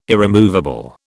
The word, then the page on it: irremovable: British English